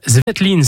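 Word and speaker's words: Svetlin: Oui, le milieu de terrain international slovène Tamar Zvetlin, c'est comme ça que ça se prononce.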